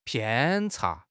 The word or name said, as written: 偏差